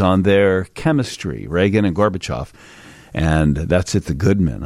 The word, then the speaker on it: Gorbachev: How do you say it, Steve? You're the Russian scholar around here. Mikhail Gorbachev. – Thank you. – I don't do Russian. I just... – Yeah, but you threw in an extra syllable there for us, I thought.